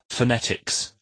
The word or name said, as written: phonetics